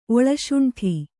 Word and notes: ಒಳಶುಂಠಿ: Kannada

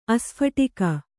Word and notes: ಆಸ್ಫೋಟಕ: kannada

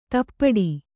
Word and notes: ದುಪಟಿ: Kannada

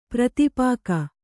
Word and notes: ಪರಿತಾಪಿಕಿ: kannada; pari tāpiki

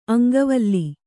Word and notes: ಅಂಕವಲ: aŋkavala

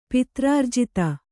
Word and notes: ಪಿತ್ರಾರ್ಜಿತ: pitrārjita

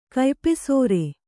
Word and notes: ಕಯ್ಪೆಸರ: kannada

